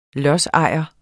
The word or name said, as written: lodsejer